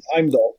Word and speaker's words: Heimdall: He's Heimdall, isn't he? – Heimdall, I think.